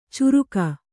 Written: ಚುರುಕ

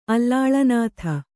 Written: ಅಲಾಳನಾಥ

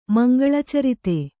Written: ಮಂಗಳಚರಿತ

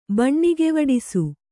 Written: ಬಣ್ಣಿಗೆವಡಿಸು

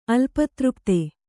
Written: ಅಲ್ಪತೃಪ್ತ

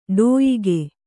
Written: ಡೋಯಿಗೆ

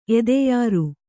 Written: ಎದೆಯುರಿ

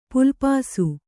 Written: ಪುಲ್ಪಾಸು

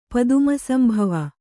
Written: ಪದುಮಸಂಭವ

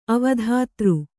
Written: ಅವಧಾತೃ

